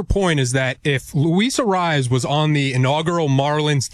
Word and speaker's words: Arraez: Arraez. – Yeah. I mean, Jesus Christ. But Luis, how do you say it Arraez. – Again? – Arraez.